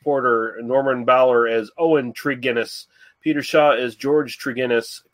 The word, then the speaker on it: Tregennis: Like, I hate to correct you, but it's a hard G, so it's Tregennis.